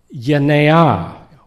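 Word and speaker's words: Yenea (γενεά): because the G is pronounced like cross between a G-H and a Y. So who cares? I'm a word guy. Yenea would be, yenea would be the better pronunciation of this word generation.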